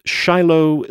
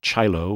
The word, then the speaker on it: ChiLo: And Shiloh City Grid. I pronounce it Shiloh, not Chilo or Chilo, because Shiloh, it's Chicago and St. Louis. And Chicago is Chi-Town.